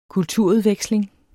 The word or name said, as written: kulturudveksling